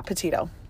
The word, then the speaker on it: Petito: Petita? – Hold on. We better get that right. – Oh, hold on. Let me check. – Gabby Petito. Yeah, Petito.